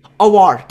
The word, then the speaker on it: Aouar: How is it? – Houssem Aouar. – Aouar, that's how you pronounce it.